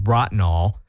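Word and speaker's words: Bratenahl: It's spelled very oddly, but it's called and pronounced Brottnall.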